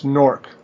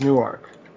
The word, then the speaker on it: Newark: Well, yeah, because so in New Jersey, it's Newark. And in Delaware, it's Newark.